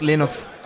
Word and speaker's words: Linux: I pronounce Linux, Linux.